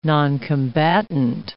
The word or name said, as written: noncombatant